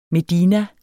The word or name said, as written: medina